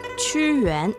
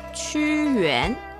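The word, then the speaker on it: Chu Yuan (屈原): Chu Yuan es el nombre de gran poeta a quien conmemora esta fiesta tradicional china. – Chu Yuan.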